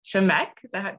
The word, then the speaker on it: Shemek: Today I have a very special guest joining me, Dr. Lulu Shemek. Did I pronounce it? – Yes. Shemek, like lick. Shemek.